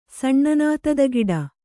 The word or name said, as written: ಸಣ್ಣನಾತದಗಿಡ